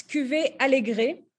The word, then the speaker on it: Alegre: Is it Alegre or is it Alegre, the 2019 Verdejo? – It's Cuvée Alegre. – Alegre. See, I mispronounced it all the different ways.